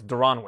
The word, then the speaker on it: Daron: Julian Marquez against Darren Wynn. Oh, actually, sorry, it's Daron Wynn. Daron Wynn. Not pronounced like Darren Williams.